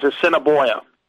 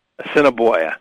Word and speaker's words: Assiniboia: But the correct pronunciation is Assiniboia. – Assiniboia.